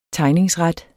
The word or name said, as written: tegningsret